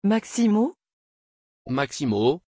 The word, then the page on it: Maximos: French